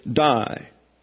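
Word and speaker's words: die (δεῖ): It is die, not D-I-E, but D-E-I. Oops. That's D-E-I, pronounced die, this being a diphthong here.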